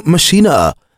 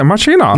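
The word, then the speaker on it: Maschine: The Machine 2 or Machina, if you're German, I think that's how they say it. – Yeah, Machina.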